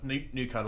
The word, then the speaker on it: Nucoa: but it's spelled Nuco like cocoa, but N-U-C-O-A.